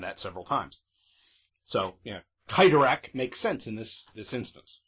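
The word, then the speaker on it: Cyttorak: Yes, and thank you for pronouncing it with a hard C, because we all know Stan Lee likes his alliteration, so it would be Crimson Bands of Kytorak, and I've heard it pronounced Sidorak, and it just makes me cringe. – Well, really, when you have the C-Y together, it is more of a hard C sound.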